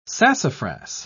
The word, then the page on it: sassafras: British English